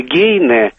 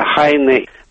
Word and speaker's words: Гейне: Немецкое «гейне» вместо «хайне». По-русски мы можем произнести, конечно, «ай», но тем не менее мы до сих пор видим именно вот это написание и произношение имени великого поэта.